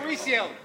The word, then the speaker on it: Recio: Daniel Riccio. – Daniel Riccio. F*** yeah. How do you spell that last part? – R-E-C-I-O.